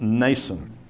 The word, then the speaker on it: Mnason: And Paul stayed with a man, I'm guessing, whose name is pronounced Mnason. It's spelled M-N, and I thought of the word mnemonic. You know, M-N, and we pronounce it mnemonic.